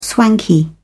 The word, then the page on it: swanky: British English